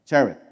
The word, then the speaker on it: Cherith: Okay, how do you pronounce that? Cherith. Okay, Cherith, which flows into the Jordan.